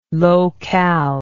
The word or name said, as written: low cal